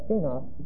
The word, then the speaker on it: skenos: The word tent in Greek is the word skenos. Let me make sure of that word. I remember the spelling of it, and I'm the world's worst speller. Yeah, skenos. It's like this. S-K-A-N-O-S. S-K-E, long E-N-O-S. And we get our word skenao from that, S-K-E-N-O-O, which means to tent, or to tabernacle, or to dwell in a tent.